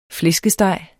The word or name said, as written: flæskesteg